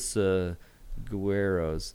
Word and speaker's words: saguaro: Lost Dutchman State Park is an area where it has tons of saguaros. – Saguaros. – Saguaros. – He's been having a hard time with it all week. – It's spelled with a G, and yet you say saguaros. – Saguaro. – Saguaro. I hate that word. – G-U-A-R-O.